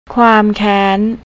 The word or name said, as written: kuamkan